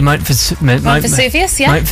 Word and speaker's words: Mount Vesuvius: Did I say that properly? – Mount Vesuvius.